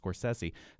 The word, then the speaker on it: Scorsese: Right, because just about everybody knows him as Martin Scorsese, though we know, as we've talked about before, his last name is pronounced Scorsese.